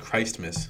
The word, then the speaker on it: Christmas: Why isn't it pronounced Christmas, for the record? Why is it Christmas? Christmas. Why is that T silent? Christmas.